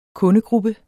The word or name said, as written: kundegruppe